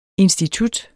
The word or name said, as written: institut